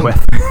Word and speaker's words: Goethe: I think it's because you realize that people are like, "Do you mean Goethe?" And it's like, "No." – Goethe. Oh, Goethe. Yeah, he's great. – Yeah, I mean Goethe. It's a weird pronunciation.